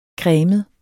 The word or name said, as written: CREMET